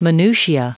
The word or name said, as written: minutia